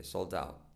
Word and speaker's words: sold out: La T de sold out casi no se dice, sold out.